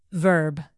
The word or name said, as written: verb